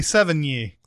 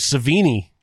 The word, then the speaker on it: Sevigny: It took until she got nominated for an Oscar for me to actually hear that name pronounced. – I thought it was Savini. – I did too. That's what I said. That's what I would say.